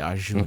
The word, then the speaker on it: Arjuna: Is it a hard Arjuna. – J? – Arjuna. I think it's soft J. Arjuna.